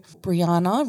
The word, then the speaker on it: Brianna: or I do the old, like, rhyme. So Brianna rhymes with banana.